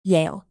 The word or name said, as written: yale